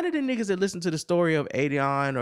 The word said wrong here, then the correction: Adidon: or however you pronounce it, Adidon, are listening to your album.